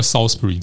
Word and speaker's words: Salisbury: 对，它就变成 Salisbury，所以你在当地念 Salisbury，你一定会被 100%、100万会被当成是外地人。 – 我相信我出现，然后讲个两句话，应该就是很像外地人。 – Salisbury 哈，我们这边更正一下，就是发音叫 Salisbury 这样子。